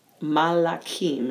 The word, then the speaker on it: Malachim: So anyway, Vayishlach picks up with, in Aliyah 1, with Jacob dispatching Malachim, is how that's pronounced in Hebrew, Malachim, to Esau to determine his state of mind.